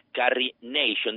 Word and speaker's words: Carrie Nation: Carrie Nation. De feito, Carrie A inicial Nation, que é un xogo de palabras co seu nome, porque pronuncias igual que a expresión Carry a Nation, que se a traducimos de inglés ao galego, pois ven dicir algo así como levar unha nación ou cuidar unha nación, non?